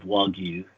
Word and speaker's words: Wagyu: But, so A5 is the highest grade of Wagyu that's out there in Japan.